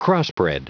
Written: crossbred

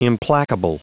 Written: implacable

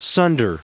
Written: sunder

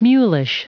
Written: mulish

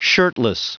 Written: shirtless

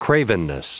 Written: cravenness

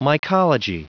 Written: mycology

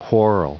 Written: whorl

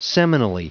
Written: seminally

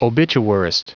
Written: obituarist

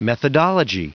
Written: methodology

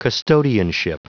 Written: custodianship

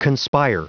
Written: conspire

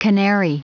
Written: canary

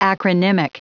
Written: acronymic

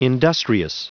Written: industrious

